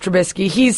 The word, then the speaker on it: Trubisky: Trubisky, Mitchell Trubisky, yes.